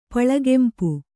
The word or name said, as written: ಪಳಗೆಂಪು